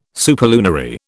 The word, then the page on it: superlunary: British English